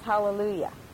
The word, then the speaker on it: hallelujah: It looks like there's no H. But in Greek, there's no letter H. But the A is H-A. So actually, that's pronounced hallelujah. The same as the Hebrew.